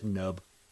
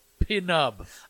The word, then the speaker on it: Penub: And Penub, and the other I like to think that the P is silent like pterodactyl, so it's just nub. – Except it's not, because they always say Pinub.